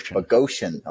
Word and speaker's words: Boghossian: Peter Boghossian, I think is how you pronounce it. – Boghossian. – Okay, there you go.